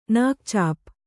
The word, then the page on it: ನಾಕ್ಚಾಪ್: nākcāp